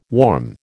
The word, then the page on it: warm: American English